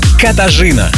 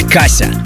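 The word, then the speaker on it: Katarzyna: Ее зовут Катажина. Катажина, а проще Каша. Но чаще ее имя произносит на английский манер Кассия или Кася.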